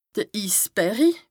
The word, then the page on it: de Issberri: Bas-Rhin; Strasbourg